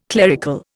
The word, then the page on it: clerical: Indian